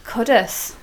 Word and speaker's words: Cudworth: was in Cudworth. – Cudduth. – Cudduth. – Cudduth. – Yeah, ring bell. – We all got shouted at for saying it wrong.